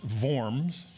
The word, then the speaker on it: Worms: And it was in the city of Worms, but that's pronounced Worms.